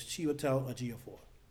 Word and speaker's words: Chiwetel Ejiofor: So it's Chiwetel Ejiofor.